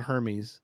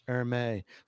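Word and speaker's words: Hermès: I know how to say it right. It's not Hermes. Hermes.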